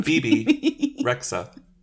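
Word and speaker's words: Bebe Rexha: Bibi Rexa.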